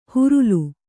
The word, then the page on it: ಹರಲು: Kannada